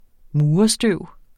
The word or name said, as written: murerstøv